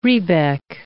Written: rebec

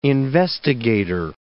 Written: investigator

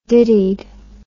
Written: dittied

